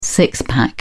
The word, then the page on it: six pack: British English